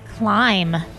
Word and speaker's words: climb: Jason, what is the silent letter in the word climb? – B. – Yes.